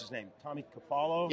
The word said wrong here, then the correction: Cafaro: Yep, Cafaro. Tom Cafaro.